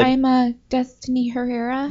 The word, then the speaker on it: Herrera: You have to roll your R's. – I'm white. Herrera. – Yeah, if you get pulled over by the police, it's Herrera. I'm Destiny Herrera.